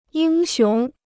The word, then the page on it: 英雄: yīng xióng